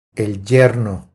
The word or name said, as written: El yerno